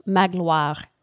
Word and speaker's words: Magloire: One, his sister, Mademoiselle Baptistine, and the other, her companion, Madame Magloire. Is that right? – Yeah. – Nailed it on the pronunciation. – You kind of create a W, Magloire, like that.